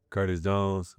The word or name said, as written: Curtis Jones